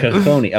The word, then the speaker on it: cojones: Cojones. Okay, you know what, yeah, you're going to pronounce it right.